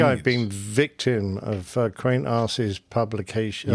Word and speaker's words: Qantas: Just some feedback regarding Qantas's accident record. I think that's the way you say that. – Yes, that's the proper pronunciation.